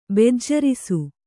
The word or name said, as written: ಬೆಜ್ಜರಿಸು